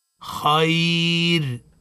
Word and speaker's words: خير: ثم نقول خير لا مش صحيح نقف عليها خير بالترقيق لان الراء ساكنه